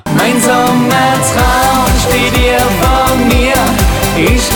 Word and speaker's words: Mein Sommer Traum: Tu vas me dire si je le dis bien. On vient d'écouter Mein Sommer Traum. – Mein Sommer Traum. – Tu le dis mieux quoi, forcément.